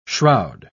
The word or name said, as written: shroud